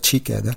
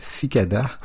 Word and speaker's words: Ciccada: Je me suis rendu compte que j'ai prononcé le nom du groupe à l'italienne, c'est-à-dire Cicada. Et c'est une formation grecque, donc Cicada.